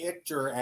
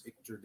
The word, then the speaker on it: Ichter: Everybody wants to spell it Ichter. It's not I-T-C-H. It's I-C-H-T-E-R. – Before we went live, I asked how to pronounce it. I said it wrong. It's like Victor without the V.